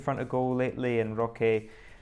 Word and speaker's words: Roque: and that is Vitor Roque, or Roque as the Brazilians pronounce it, and we should probably too.